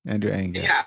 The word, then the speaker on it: Andrew Ng: Oh, Andrew Ng? Andrew Ng. – Yeah, Andrew Ng. Just completely butchered the last name, sorry.